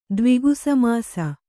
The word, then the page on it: ದ್ವಿಗುಸಮಾಸ: Kannada